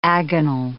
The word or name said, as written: agonal